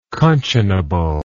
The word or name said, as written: conscionably